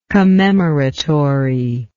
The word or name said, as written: commemoratory